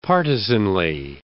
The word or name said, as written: partisanly